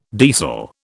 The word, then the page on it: deasil: British English